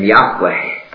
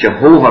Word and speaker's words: Yahweh: Whenever we see that word Lord in our Bibles in capital letters like this, it's God's own special name, Yahweh. We sometimes pronounce it Jehovah or speak of Jehovah, but it was never pronounced that way.